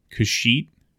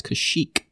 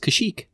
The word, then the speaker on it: Kashyyyk: Kashyyyk. – Why do you say it like a sheet of paper? It's Kashyyyk. – Shakira. Does it have a K at the end? – It does. Kashyyyk.